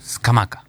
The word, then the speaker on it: Scamacca: Scamacca, ¿no? – Scamacca. – Qué bien que lo dice, fiscal. – Es como se pronuncia, ¿no? No es que yo lo digo bien o mal.